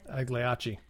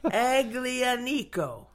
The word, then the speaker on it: Aglianico: Agliacci. – Yahtzee! Aglianico.